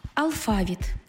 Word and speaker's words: алфавіт: Наголос у слові «алфавіт» на другому складі основний у нашій мові, бо збігається з грецьким «алфавітон», від якого й походить українська лексема.